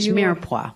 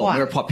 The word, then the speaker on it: mirepoix: M-A-R-I... – It's French, mirepoix. – Oh, mirepoix, P-O-I-S. Okay. – P-O-I-S, yeah. – Right. – So, of your carrot, celery, and onions. – Mirepoix.